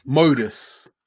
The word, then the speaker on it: Modus: Renault and Mundus spelt M-O-D-U-S. – Everyone listening? Mundus or Modus? – Mundus, it doesn't matter. – There's no N.